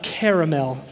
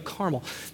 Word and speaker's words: Carmel: This is a story, not Carmel. Not caramel, depending on how you pronounce it, but Carmel.